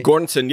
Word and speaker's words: Göransson: I was like, man, what an interesting challenge for, I'm going to butcher their name, Ludwig Göransson. Is that how you say? – Göransson, yeah.